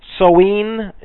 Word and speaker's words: Samhain: His holiday was called the Vigil of Samhain or Samhain, pronounced Soween, and that's the way they say it in the Celtic language, I guess.